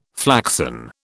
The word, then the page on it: flaxen: British English